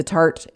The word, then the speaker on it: Tarte: but it is Tarte makeup. Tarte is spelled T-A-R-T-E.